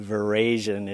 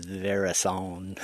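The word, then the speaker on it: veraison: We call it Verasian if you're from Eastern Washington, but I think the official pronunciation is Verason.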